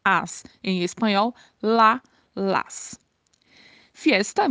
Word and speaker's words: fiesta: Que é festa, mas lembrem-se que esse i tem que ser bem marcado aí na pronúncia, fiesta, para não ficar parecendo o português, né? Falando festa.